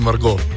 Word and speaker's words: Margot: Pa smo pa rekli mini Margot. In pa malo smo ga pofrancozili, tak da se T ne izgovarja.